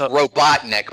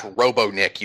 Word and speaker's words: Robotnik: It's pronounced Robotnik, by the way, not Robonik, you dumbass.